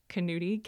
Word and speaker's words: Knutti: My name is Jeanette Knutti, K-N-U-T-T-I, because no one ever knows how to spell it.